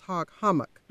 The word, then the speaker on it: Hog Hammock: And I should point out that that although the name is Hog Hammock, H-A-M-M-O-C-K, the people here pronounce it hummock, and which is another word, but the name Hog Hammock is the one you usually see on the signs and in books. – Okay, but it's pronounced Hog Hummock.